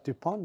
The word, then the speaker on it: Dupont: Yeah, DuPont. – DuPont not being there. – Silent T.